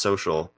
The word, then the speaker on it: Socl: S-O.C-L. According to Forbes, it is technically supposed to be pronounced social, but I disagree. – Sokol is the answer.